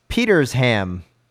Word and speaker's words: Petersham: Looking at you, Tim, and pronounced the town name Petersham like you. I was quickly corrected by a townie. It's Petersham, Flatlander.